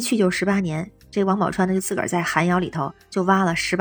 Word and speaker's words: 王宝钏: 比如说最近特别流行那个王宝钏挖野菜那个事儿，当然有人说规范的读音叫王宝钏啊，但是咱们大众好像说王宝钏比较多，所以我就还说王宝钏吧。